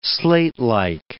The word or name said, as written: slatelike